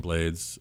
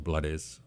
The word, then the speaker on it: Blades: But this, just for the fact that the co-writer on this is Ruben Blades, or Blades, however you want to pronounce it, yeah.